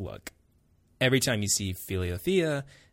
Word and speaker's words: Philothea: Philiothea, I think is how you pronounce it. Philiothea. That's how I say it.